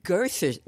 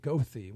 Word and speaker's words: Goethe: That's in Chicago, that's the correct pronunciation. One of my voice elocution teachers who's spent a lot of time in Chicago also was like, everybody pronounces it Goethe when it's Goethe.